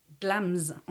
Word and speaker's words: Glamis: Et donc, je suis tombée sur le château de Glamis. On dit Glamis, mais ça s'écrit G-L-A-M-I-S. Glamis. Mais dites pas Glamis.